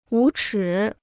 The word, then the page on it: 无耻: wú chǐ